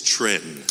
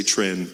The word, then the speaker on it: Chuan: It's spelled C-H-U-A-N. – Es ist C-H-U-A-N. – Okay. It may look like Tran, but it's not. – Es sieht aus wie Tran, aber es ist nicht. – And this is how you pronounce it. You cover my name and you just say Tran.